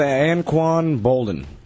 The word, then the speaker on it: Anquan Boldin: I'm going with Anquan Boldin, even though I don't know how to say his name.